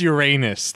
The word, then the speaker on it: Uranus: Whoa, it's Uranus. Thank you. – Yeah, please get the pronunciation right.